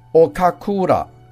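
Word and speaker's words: 岡倉: 冈仓天心日本的名字的读音是读成 Okakura。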